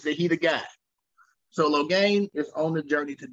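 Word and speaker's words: Logain: Loghain, I always said Logian, but Loghain. – You heard them say it, then you're like, oh, that's how you pronounce it.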